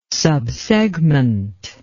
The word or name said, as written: subsegment